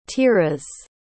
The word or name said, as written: Tiras